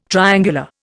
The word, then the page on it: triangular: Indian